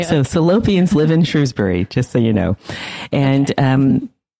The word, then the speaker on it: Shrewsbury: I should also say I am pronouncing this town as Shrewsbury, although it is also pronounced by locals as Shrewsbury.